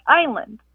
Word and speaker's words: island: Why is it island always spelled like island, and why do we always pronounce it like island? – Oh, what a good question. Why does island have an S in it, and why is it pronounced with a long I?